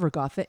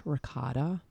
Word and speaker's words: ricotta: Okay, so my favorite meal or my death meal, it has to have ricotta in it, which you guys will pronounce ricotta.